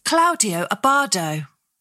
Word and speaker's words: Claudio Abbado: Apologies, that might have been terribly pronounced. Claudio Abbado. Why can't I speak? God. – Go on, have another run up at it. – Claudio Abbado. Yeah? – That sounds good to me.